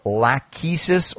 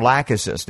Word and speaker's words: Lachesis: But one of my favorite remedies is called lachesis or lachesis, depending on. It's Latin, so we can get away with either pronunciation. And that's spelled L-A-C-H-E-S-I-S.